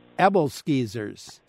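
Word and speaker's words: æbleskiver: Rachel, our Central Time producer, studied in Denmark, and she remembers them being pronounced in Denmark as Ebbelskeevers. – Okay. – So I guess, you know, if that's the way they pronounce it in Denmark, I guess probably that's the way we ought to pronounce it here.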